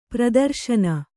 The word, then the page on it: ಪ್ರದರ್ಶನ: pradarśana